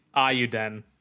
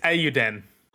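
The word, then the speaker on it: Eiyuden: There is literally a Google video on how to pronounce this. It's Iyuden. Iyuden. Okay. Oh, goddamn, I've forgotten already. Give me one second. It's Iyuden.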